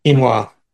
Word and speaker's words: quinoa: Quino, quino, Quinoa.